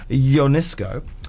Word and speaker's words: Ionescu: First play written by Romanian writer Eugen Ionescu.